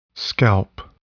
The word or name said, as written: scalp